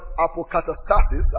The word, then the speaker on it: apokatastasis: The word restitution is taken from the word, I'll just give it quickly because some of you who study, is the word apokatastasis. I'll spell it, don't bother. A-P-O-K-A-T-A.